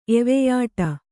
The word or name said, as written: ಎವೆಯಿಡು